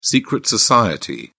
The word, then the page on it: secret society: British English